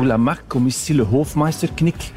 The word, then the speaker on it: Hofmeister: Tu as souligné le pli de Hofmeister, c'est comme ça, c'est une bonne Hofmeister, prononciation. Excusez-moi.